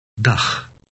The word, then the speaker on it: dag: It resembles the ending of the Scottish word loch, but a little softer. Listen again. – Dag.